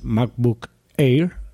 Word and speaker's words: MacBook Air: Entonces, la filtración se basa en que eh, Apple sacaría unos nuevos MacBook Air.